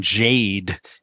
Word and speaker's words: Jagde: The tenor who I mentioned before, his name is apparently it's pronounced Brian Jade, even though it's spelled J-A-G-D-E.